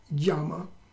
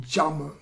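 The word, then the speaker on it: Jama (यम): So Asteya is one of the points of Yama, or more correctly called Jama, because the J in the Y, when it comes in the beginning of a word in Sanskrit, can be pronounced, it should be pronounced as a J.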